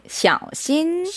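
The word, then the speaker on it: 샤오신 (小心): 작을 쏘 마음 신자를 쓰고요. 그 삼성 일성해서 발음은 샤오신입니다. – 네, 샤오신. – 샤오신. – 네, 좋았어요.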